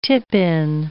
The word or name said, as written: tip-in